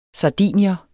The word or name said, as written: sardinier